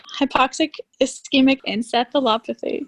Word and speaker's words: hypoxic ischemic encephalopathy: Luckily, St. Joe's had that option, and was diagnosed with HIE, which is known as hypoxic ischemic encephalopathy. – Did I say that? You said it right.